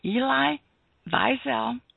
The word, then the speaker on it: Wiesel: The book's main concern is to present the world's first unauthorized biography of Elie Wiesel, and that's how I was taught to pronounce it when I went to a conference where he spoke.